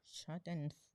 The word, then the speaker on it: Schadenfreude: Schadenfreude. – Schadenfreude.